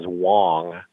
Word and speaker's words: Wong: My last name is Wong, W-O-N-G, not Wan or Long.